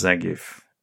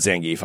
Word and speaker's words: Zangief: I think it's pronounced Zangief. – I'm sorry. I apologize. – Street Fighter character. – Oh, yeah, it's Zangief. You're right. It is not Zangief.